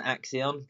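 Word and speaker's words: Axeon: You've been snapped up by Axel Merckx's team, the very famous, the very wanted team by many young cyclists, that Hagens Berman Exxon, am I pronouncing that one right, mate? – Hagens Berman Axion, but yeah, not bad, mate, not bad.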